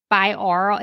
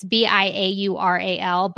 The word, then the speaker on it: binaural: Just if you Google, you know, I always mispronounce this Bioral. It's Biaural, Bioral Beats.